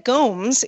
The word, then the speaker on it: Gomes: Ben Gomes is my fiance's cousin. I just want to correct the pronunciation of his last name. That was me. It's pronounced like Holmes, not like Gomez, as in Gomez Adams.